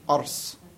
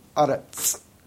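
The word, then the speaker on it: aretz (ארץ): It depends on how you pronounce the sod. Is a velar or is it TZ? You can either way. Technically, ancient Hebrew, it pronounced it as a velar, so, ars. But everyone today says aretz.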